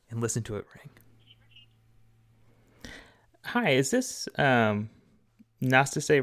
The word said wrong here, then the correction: Nastasi: Roofing? Am I saying that right? I don't know. Nastasi. Okay, Nastasi.